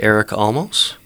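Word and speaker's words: Eric Almas: One person that I like his work a lot would be Eric Almos. Okay, that's how you pronounce his name correctly.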